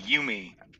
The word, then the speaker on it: Jumi: Did I say that right, Jumi? – It's like you and me, Yumi. – Yumi. Okay. Yeah. I knew I was getting it wrong.